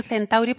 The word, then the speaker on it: centauri: Tienes que decir centauri porque lo estamos pronunciando a la española. Una cosa sería la prosodia, ¿eh? la, acentuación, pero esa C ahí está perfecta.